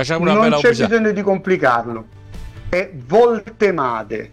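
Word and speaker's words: Woltemade: La tendenza è sempre a complicarlo un po', perché in tedesco c'è la V semplice che si pronuncia F, ma la doppia V si pronuncia V. – Come lui non è Voltemade, ma è Voltemade.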